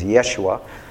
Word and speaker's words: Yeshua: or the proper pronunciation in Hebrew is Yeshua.